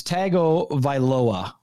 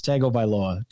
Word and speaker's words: Tagovailoa: Well, and I keep getting his name wrong. It's Tagovailoa. We just got to remember that. It's Tagovailoa. Tua Tagovailoa.